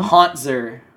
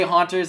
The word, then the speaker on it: Hauntzer: By the way, can you please pronounce the name of the TSM top laner? The people at home? – Hauntzer. – There you go. People get really mad at you. – Listen, I have to talk. Mad. I have to talk for like an hour here explaining all my thoughts. Like, I'm sorry that I mispronounce it, but it's easier to say Hauntzers than it is to say Hauntzer.